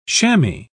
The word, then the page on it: chammy: British English